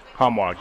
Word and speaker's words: Hanwag: and is it Hanwag? – Hanwag, yes. – Hanwag, okay, I said it right.